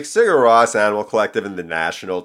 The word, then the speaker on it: Sigur Rós: Sigur Rós. Did I say that right? – Sigur Rós. That's how I've been doing it.